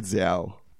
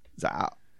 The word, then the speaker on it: Zao: I've got a lot of different opinions on how to pronounce it on the internet, but I get Zao. Z-A-O. Z-A-O. – Zao. – It's just Zao.